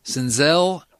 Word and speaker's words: Senzel: And for those of you that keep mispronouncing it on the broadcasts, I keep hearing Senzel. Senzel as in Denzel.